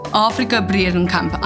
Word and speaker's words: Afrika-Bredenkamp: Normally, you should just pronounce the R, otherwise they call me Africa. – So Africa. – I'm Afrika-Bredenkamp. – Afrika-Bredenkamp.